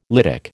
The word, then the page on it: lytic: American English